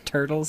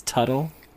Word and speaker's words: Tuttle: I've heard it pronounced turtle sometimes, but it's Tuttle with a T.